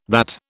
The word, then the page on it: that: British English